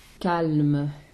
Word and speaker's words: calme: Calme. – So you have to say the L. – Oui. – And the exactement. Same thing with... – Berce sa palme. – Yeah, so the L is actually pronounced.